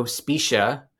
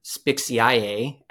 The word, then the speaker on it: Spiccia: And today I am joined by one of my good friends, Joe Spiccia, or Spixiae, as Siri likes to pronounce his last name.